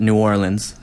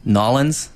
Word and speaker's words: New Orleans: Well, firstly, I got schooled down there when I called it New Orleans. You have to say, like, Nolens. Otherwise, you get laughed at. – How do you say it? – Nolens.